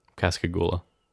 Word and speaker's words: Pascagoula: Pascagoula.